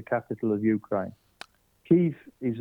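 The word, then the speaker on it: Kyiv: And now that they, obviously, we're learning of this now because Ukraine being obviously in the news, but it's Kiev is the pronunciation.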